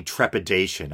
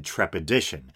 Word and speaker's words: trepidation: Well, I was listening back, and I realized that I mispronounced one word. Instead of saying trepidation, I said trepidition.